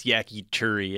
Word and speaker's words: yakitori: By the way, Miles, I think you mixed up the pronunciation. It's yakitori, as I said.